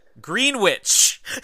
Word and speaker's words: Greenwich: Greenwich is the way that we pronounce it. Like New Orleans, Melbourne, and Toronto.